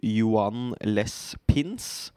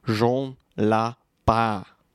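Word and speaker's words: Juan-les-Pins: Og det stedet jeg er på nå, det heter, eller det skrives, Joan Les Pins. Og så uttales det Jean Lapin. Jean Lapin.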